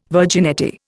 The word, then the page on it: virginity: Indian